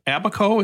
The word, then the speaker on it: Abaco: You visited one called Abaco. Is that the way you pronounce it? – That's right.